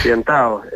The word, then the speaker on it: Piantaos: con el título de Piantaos. ¿Lo he dicho bien? – Sí, lo has dicho muy bien. Sí, sí, Piantaos.